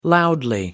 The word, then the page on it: loudly: American English